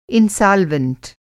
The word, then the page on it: इनसालवेंट: انسولینٹ